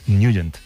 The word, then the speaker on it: Nugent: Siempre lo hemos llamado en el barrio Tednagent o Tednagent. – Claro, ¿no? Yo era de Tednagent. – Pero la pronunciación correcta es Nugent.